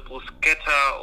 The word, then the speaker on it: Bruschetta: Also kannst du ja im Italienischen H macht H. Wenn dann nach dem C ein H kommt, dann wird das K ausgesprochen. Also Bruschetta und Latte Macchiato und nicht Latte Macchiato oder so eine Scheiße.